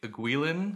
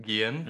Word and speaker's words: Guillen: Jose Guillen, I'm probably mispronouncing that. – I think it's probably Guillen. – Guillen. – Yeah.